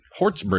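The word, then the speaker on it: Hjortsberg: Written by William Hortzberg. Hortzberg is how you say it. – That's how I've always said it.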